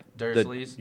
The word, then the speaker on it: Dursleys: Dursleys. – I said that wrong. – Yeah, the Dursleys.